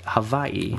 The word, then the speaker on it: Hawaii: When I say it Hawaii.